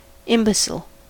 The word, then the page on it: imbecile: American English